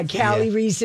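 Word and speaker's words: Kali Reis: Yeah, Callie Reese, and it's spelled K-A-L-I and then R-E-I-S.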